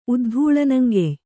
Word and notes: ಉದ್ಧೂಳನಂಗೆಯ್: Kannada

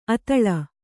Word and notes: ಅತಲ: Kannada

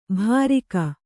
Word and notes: ಭಾರಿಕ: Kannada

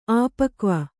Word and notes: ಆಪಕ್ವ: āpakva

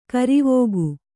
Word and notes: ಕರಿವಕ್ಕಿ: karivakki

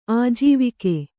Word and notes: ಆಜೀವಕ: ājīvaka

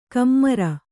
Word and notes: ಕಮ್ಮಾರ: kammāra

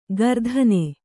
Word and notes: ಗರ್ಧನೆ: gardhane

